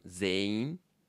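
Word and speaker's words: zain (全員): And finally, like if you want to say like everyone in a room, it's zain. – Zain. – Even though it's written zen-in, you would say zain.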